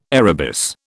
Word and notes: arabis: British English

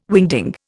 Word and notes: wingding: Indian

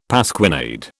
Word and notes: pasquinade: British English

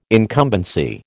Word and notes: incumbency: American English

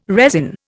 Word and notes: resin: Indian

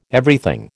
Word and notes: Everything: American English

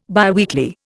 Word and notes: biweekly: Indian

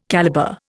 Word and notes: calibre: Indian

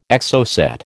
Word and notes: Exocet: American English

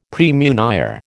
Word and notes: praemunire: American English

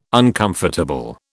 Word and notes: uncomfortable: British English

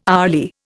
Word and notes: hourly: Indian

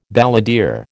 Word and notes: balladeer: American English

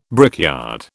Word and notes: brickyard: British English

